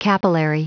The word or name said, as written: capillary